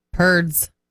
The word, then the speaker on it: Perdz: Perds? Perdez? Sure. P-E-R-D-Z. – Perds. – Perd rhymes with turds.